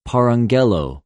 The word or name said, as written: παραγγελλω